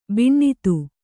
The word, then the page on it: ಬಿಣ್ಣಿತು: kannada